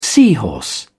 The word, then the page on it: seahorse: British English